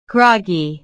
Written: groggy

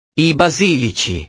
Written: i basilici

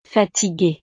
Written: fatigue

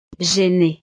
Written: gêné

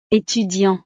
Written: etudiant

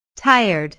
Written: tired

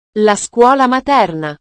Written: la scuola materna

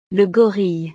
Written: le gorille

